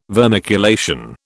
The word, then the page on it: vermiculation: British English